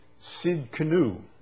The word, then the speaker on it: Tsidkenu: It's Yahweh Tsidkenu. Yahweh Tsidkenu, it's spelled T-S-I-D-K-E-N-U, Tsidkenu.